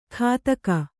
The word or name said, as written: ಖಾತಕ